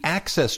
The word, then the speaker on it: AXS: On the Access Channel, AXS, pronounced Access, there's this thing called Dan Rather's Big Interview.